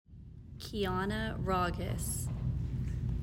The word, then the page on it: Kiana Raagas: KEY ah nah RAH giss